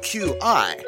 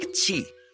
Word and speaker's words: qi: But to really understand why, you must first understand something called qi, spelled Q-I, but pronounced like qi. – Did you say cheese? – No, qi.